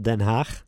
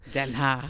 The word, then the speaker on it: Den Haag: How do you pronounce it? The Hague? – The Hague. It's the called Hague. In the Netherlands it's called Den Haag. – Den Haag.